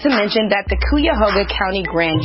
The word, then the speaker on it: Cuyahoga: how to pronounce the name of the county. It's Cuyahoga County.